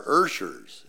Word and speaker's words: usher: And he said, those are Urshers. It's spelled U-S-H-E-R, but it is pronounced Ursher.